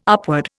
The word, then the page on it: upward: Indian